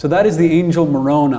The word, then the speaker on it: Moroni: Yeah, it's Moroni. – Moroni. – Yeah. – Okay, good. – Is the correct way to pronounce it.